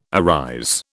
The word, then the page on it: arise: British English